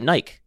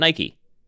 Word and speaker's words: Nike: In the UK, they pronounce it Nike, not Nike.